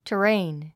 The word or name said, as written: terrain